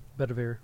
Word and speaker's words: Bedivere: Belvedere? Is that Bedivere? – Yep, Bedivere.